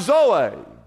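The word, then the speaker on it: zoe (ζωή): It says, I've come to give you, and he uses the word zoe, Z-O-E, but it's pronounced zoe. Everybody say zoe.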